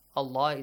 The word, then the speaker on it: الله: Allah is pronounced heavily here because there is a fatha sound before.